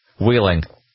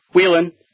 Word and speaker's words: Wheeling: Wheeling. – Close. Wheeling. – Wheeling. – You're in Wheeling. – Okay. Wheeling. They take away the G at the end.